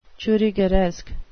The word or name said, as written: churrigueresque